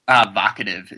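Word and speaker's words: Vocativ: Vocative. It's like provocative.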